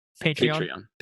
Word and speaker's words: Patreon: I would say, because we put up this Patreon. That's how you say it, right? – Patreon.